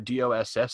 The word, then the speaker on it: DOSS: So they now use what they call the DOSS system. So you pronounce it DOS.